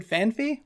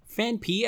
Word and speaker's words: Phanpy: Fanpi, actually, it's Phanpy. – Okay. – But a lot of people do say Fanfi. – Okay. – And I do too sometimes, but then I remember how it's spelled.